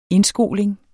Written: indskoling

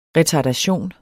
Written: retardation

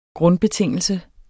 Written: grundbetingelse

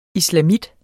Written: islamit

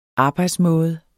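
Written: arbejdsmåde

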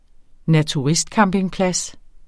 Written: naturistcampingplads

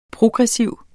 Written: progressiv

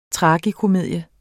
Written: tragikomedie

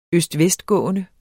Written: gak

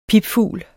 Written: pipfugl

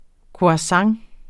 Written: croissant

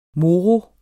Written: moro

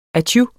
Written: atju

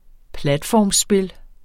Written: platformspil

